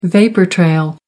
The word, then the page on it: vapour-trail: American English